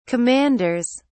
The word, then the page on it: commanders: British English